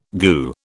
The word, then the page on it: goo: British English